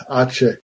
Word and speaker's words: ache: Now, ache actually comes from an Middle English word, and it should be pronounced H, but it isn't because in the Renaissance, people decided that it probably came from akos in Greek. It doesn't come from akos in Greek. It comes from ache in Anglo-Saxon. Anyway, we pronounce that word ache. Another huge exception is the word asthma. Now, asthma was spelt A-S-M-A, asthma, as you would expect, in Middle English.